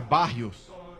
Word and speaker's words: Barrios: Porque, primeiro, o nosso amigo Bários não joga. O Etevaldo não joga, cara. – Soletre Bários. – O Bários, pô, falei certo. – É Barrios. – Ah, tá bom, desculpa aí. O Barrios.